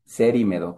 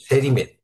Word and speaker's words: Cerimedo: lo dije bien, Cerimedo, ¿no? – Cerimedo con I. – Cerimedo. Cerimedo con C.